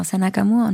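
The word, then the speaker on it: Senna Gammour: Mit Senna, wie spricht man sie aus? Senna Gamour? – Genau, Senna Gamour und Mandy Capristo, ja.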